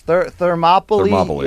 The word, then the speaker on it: Thermopylae: What was that thing I butchered the last time? Thermopile or something. – Yes. Thermopylae. Yes.